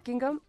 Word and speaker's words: Guingamp: I was in Guingamp. Did I say that right? – China? – Say that again. – Guingamp. – What's Guingamp? – There we go. – Perfect.